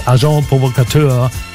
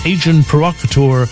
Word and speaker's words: Agent Provocateur: Unser Album der Woche, ein Klassiker-Album aus den 80ern, nämlich Agent Provocateur oder englisch ausgesprochen Agent Provocateur von der Gruppe Foreigner mit I Wanna Know What Love Is, That Was Yesterday und eben gehört Down on Love.